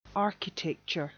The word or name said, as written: architecture